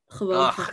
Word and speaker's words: Gewoon: No, they have the softer G, so everything is gewoon lachen en graf.